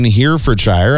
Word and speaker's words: Herefordshire: The popular Scoop ice cream shack in Herefordshire, I actually said that properly.